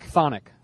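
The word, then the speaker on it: chthonic: Phonic.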